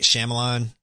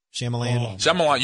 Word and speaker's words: Shyamalan: Night Shyamalan? – Shyamalan. You said it right the first time.